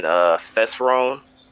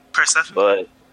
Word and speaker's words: Persephone: And what that show is, a series is basically about is, it's a retake on the tale of Hades and Thesperone. I don't know if I'm pronouncing that correctly. – Persephone.